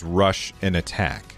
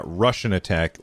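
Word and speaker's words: Rush'n Attack: Rush and Attack. And just be be sure to pronounce it correctly. It's not Russian attack like the attack by the Russians. It is Rush and attack, but they apostrophed the hell out of the and button, so it's just rush and attack.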